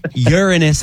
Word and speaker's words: Uranus: It's Uranus. Not Uranus, bunch of immature individuals. Uranus, that's how you're supposed to pronounce it.